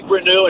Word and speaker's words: Reneau: Yeah, it's Malik Renew, and I'll tell you one other.